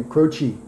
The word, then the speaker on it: Croce: They were going to spell it phonetically. C-R-O-C-H-E-E. So it looked like almost Indian, like a Native American name, Croce.